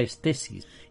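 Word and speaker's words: estesis (αἴσθησις): La palabra estética se deriva de una palabra griega que es aisthesis, que se pronuncia estesis y que es sensación, también significa percepción.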